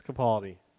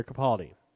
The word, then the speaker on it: Capaldi: His name is spelled Capaldi, not Capaldi. – It's Capaldi. It is Peter Capaldi.